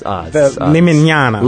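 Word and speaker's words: Limiñanas: On the Hozak label, the Limananis. Limananas? How would you pronounce that? Limananis. – Limananas. – Limananas, thank you.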